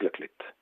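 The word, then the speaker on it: La Clayette: 3 €, le prix est vraiment très très petit, Le Petit Rameur, 31 rue Lamartine, 71 800, La Clette, qui s'écrit La Cléette, mais qui se prononce La Clette.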